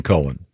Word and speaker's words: Cohen: Leonard Cohen. – Cohen. Yeah, you. – This set's confusing because... – No, not when it's spelled C-O-H-E-N. – Yeah, it's definitely Cohen.